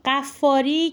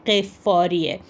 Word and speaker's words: غفاری: یک نکته رو قبل از اینکه من این بحث رو توضیح بدم عرض بکنم خدمت دوستان که غفاری که الان معروف ابوذر غفاری، این غلطه و تلفظ صحیح این کلمه ابوذر غفاریه.